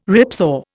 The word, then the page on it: ripsaw: Indian